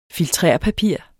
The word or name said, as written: FILTERPAPIR